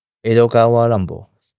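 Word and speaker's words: Edogawa Rampo: 他的笔名江户川乱步 Edogawa Rambo 就取自于爱伦坡的日文发音。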